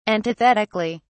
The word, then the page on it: antithetically: British English